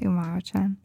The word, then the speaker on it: Umaru-chan: Umaru-chan? – Yeah.